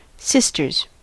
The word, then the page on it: sisters: American English